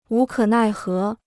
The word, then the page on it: 无可奈何: wú kě nài hé